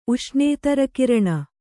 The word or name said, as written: ಉಷ್ಣೇತರಕಿರಣ